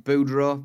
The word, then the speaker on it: Boudreau: I'm gonna say this wrong. Pierre Boudreau. – Boudreau, maybe. – Boudreau, that sounds about right.